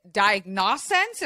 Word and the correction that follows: Diagnonsense: Diagnos- Diagnonsense. – Diagnonsense. – Just so like nonsense.